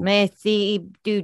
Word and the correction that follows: merci beaucoup: Did I get it? No. Merci beaucoup. Beaucoup.